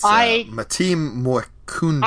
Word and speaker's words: Matim Mwekundo: Mwint Makendu, Life Improved, or something like that. Yeah, it's Matim Mwekundo.